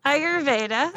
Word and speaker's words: Ayurveda: Ayurvedic, whatever it is. You know, my wife said, wow, I butchered that, but you know what I'm saying, right? – Yeah, yeah. Ayurveda.